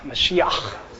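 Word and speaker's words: Mashiach (מָשִׁיחַ): I think it's pronounced Mashiach. Help me with this. Mashiach? Ha, Mashiach. Hucking loogies here.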